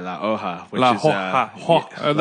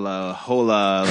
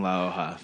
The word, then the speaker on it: La Hoja: That's spelled H-O-J-A. – H-O-J-A, which is the fucking biggest thing. We get La Hoja, La Hola. And I'm like, it's fucking La Hoja. Figure it out. Which we actually just made shirts that said, like, had the definition of the word, how to pronounce it.